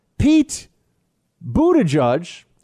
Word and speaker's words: Buttigieg: Fox has Poot, uh, Pete Buttigieg.